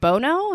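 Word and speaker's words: Bonow: Right, because it's B-O-N-O-W. And I'm like, Bono? And she's like, no, it's Bono.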